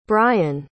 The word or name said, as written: brayan